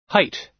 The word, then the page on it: height: American English